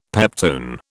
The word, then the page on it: peptone: British English